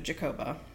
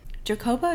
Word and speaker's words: Jacoba: Jacoba is such a different name. I've never heard it before. – It is a different name. It's like Jake. It's spelled Jacob A. – Yeah. – J-A-C-O-B-A. Jacoba.